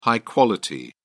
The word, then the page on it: high quality: British English